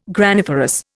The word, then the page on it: graniferous: Indian